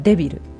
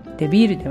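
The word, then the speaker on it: débil: で、今まで出てきた単語では例えばデビル、デビールではなくてデビルなので E の上にアクセント記号をつけます。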